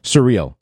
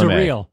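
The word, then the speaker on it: Cyrille: Some how do you pronounce Cyril? – Surreal. – Cyril. Surreal.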